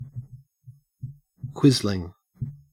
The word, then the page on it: quisling: Australian English